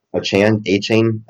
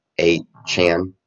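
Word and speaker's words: Achane: Devon A-Chain, I think he wants us to pronounce his name. A-Chan, not Chain.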